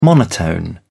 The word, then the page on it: monotone: British English